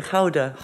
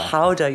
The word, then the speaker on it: Gouda: Um, how do we pronounce it? Howda. Howda. – Howda, you see, I got it right.